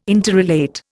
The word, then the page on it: interrelate: Indian